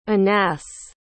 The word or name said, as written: Anass